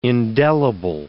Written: indelible

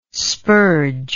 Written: spurge

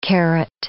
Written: karat

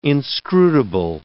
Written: inscrutable

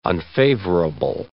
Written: unfavorable

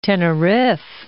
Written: Teneriffe

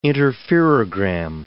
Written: interferogram

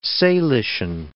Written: Salishan

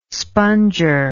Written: sponger

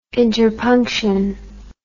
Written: interpunction

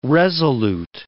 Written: resolute

